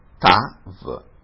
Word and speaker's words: Tav: Qui succede un po' come prima, la Yud centrale praticamente non si pronuncia e si legge Tav.